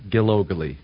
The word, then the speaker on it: Gilogley: Dirk, it's just like it's spelled, Gilogley.